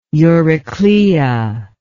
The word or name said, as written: euryclea